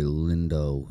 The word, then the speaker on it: Lindo: Delroy Lindo, that's how you say his name, man, he killed it.